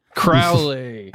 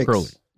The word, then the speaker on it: Crowley: Rhymes with Owly. – Oh, Alistair, our boy Allie. – Crowley. – Allie yes. Crowley. – Yes, the B66. Crowley.